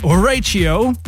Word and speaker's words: Horatio: It's Horatio. It's like English. Horatio. Oh, now I remember how Carl Cox was pronouncing it in those videos. – Yes. – Welcome to the show, Horatio.